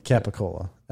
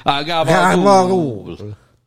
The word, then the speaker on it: capicola: Yeah, that's what the Americans say, capicola. And then, you know, Italians. – Gabagool.